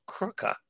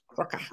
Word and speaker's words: Krka: Oh yes, that's that's me at the uh, Crooker National Park. – Yeah, that was I wasn't sure how to pronounce that. Crooker. It's, yeah. It's like K R K A or something. Crocker.